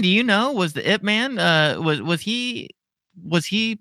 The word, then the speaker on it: Ip Man: It's from a movie called Ip Man. It's pronounced IP Man.